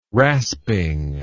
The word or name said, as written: rasping